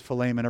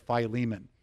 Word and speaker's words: Philemon: There's a verse in the one chapter book toward the end of the New Testament called the Greek, the way that you would pronounce it in Greek is Philemon. Everybody says Philemon.